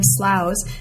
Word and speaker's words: sloughs: the nine sloughs, and thank you very much for explaining how that's pronounced for those of us. It's like plows or cows, I think you said, who don't know how to pronounce that, and I was one of them.